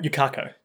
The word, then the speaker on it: Yukako: I believe it's Yukako. Like, you can't go, but without the nt. So it's just Yukako.